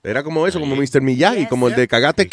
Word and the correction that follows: Karate: Kid. – Karate. – ¿Qué? ¿Eh? Karate. Eso fue lo que yo dije. – Cagate. yo tengo un frenillo, eh, cagate aquí. Acuérdense que yo no puedo pronunciar, yo no puedo pronunciar la R.